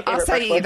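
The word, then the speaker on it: açaí: And then I love acai bowls. – Yeah, acai, that's how you say it. I can never say that word right.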